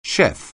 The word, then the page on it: chef: English